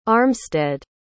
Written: armstead

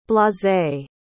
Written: blasé

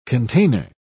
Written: container

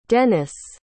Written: denys

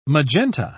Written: magenta

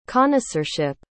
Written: connoisseurship

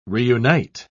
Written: reunite

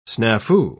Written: snafu

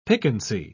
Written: piquancy

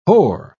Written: hoar

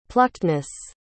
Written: pluckedness

